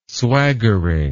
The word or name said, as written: swaggering